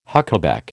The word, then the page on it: huckaback: American English